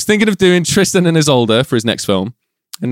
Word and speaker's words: Isolde: I'm going to butcher this. – Isolde. – Oh, thank you. He was thinking of doing Tristan and... – Isolde. – Isolde. Yeah. Good.